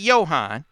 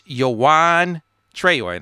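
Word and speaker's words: Juwan Traore: I was saying his name wrong. Found out his name is not Johan. It's Juwan Treyor. And Juwan should play for a Juwan, right? you would think. But Juwan Treyor is a guy that Michigan had been tracking all summer.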